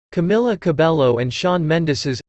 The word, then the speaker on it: Cabello: Cabello, pronounced C-A-B-E-Y-O, is set to perform at the Grammys on Sunday, January 26.